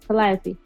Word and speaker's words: Calliope: Did I say her name right? – Yes. Calliope.